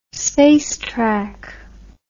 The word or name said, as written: spacetrack